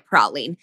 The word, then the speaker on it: praline: Where all of the praline, and we are saying it differently than you want to be hearing it, but it is how they say it in the film. – Exactly. In the South.